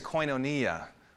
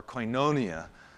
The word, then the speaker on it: koinonia: The Greek word there is koinonia, or koinonia, depending on how you pronounce it.